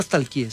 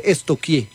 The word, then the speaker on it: stalkear: Porque no se dice nunca stalkeé, se dice estoquié. – ¿Estoquié? – Sí. Pregúntele a la profe, verá. – Estamos estoqueando. – Sí, estoqueando. Yo, ay, estoqueando. Pero se escribe stalkear. Es estoquear.